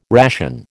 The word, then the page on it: ration: American English